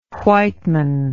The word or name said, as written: white man